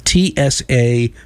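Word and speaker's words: Tsapiky: And just so you know, the name of the style, it's spelled Sapeeky, and you're supposed to drop the Y or it's silent. Sapeek music.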